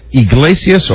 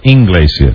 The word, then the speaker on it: Iglesias: Is it Iglesias or Inglesias? – That's why he likes me best. – Because you mispronounce his name? – Julio Iglesias. – That's correct.